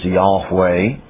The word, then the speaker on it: Yahweh: the divine name which we best pronounce Yahweh is found in the Hebrew text at those locations.